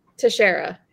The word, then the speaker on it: Teixeira: It's just one of those names I can never – Teixeira? – Teixeira, yeah.